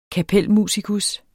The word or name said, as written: kapelmusikus